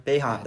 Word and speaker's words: Boeheim: I don't know how to say his last name. Boeheim, that's how you say it.